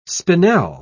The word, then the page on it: spinel: British English